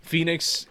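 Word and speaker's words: Fenyx: Phoenix is spelt stupidly. It's F E N Y X.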